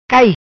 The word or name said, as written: Cai